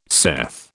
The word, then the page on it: saith: British English